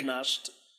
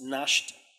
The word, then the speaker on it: gnashed: Not gnashed. Yeah, the G is silent. Just in case you didn't know. It's gnashed at him with their teeth.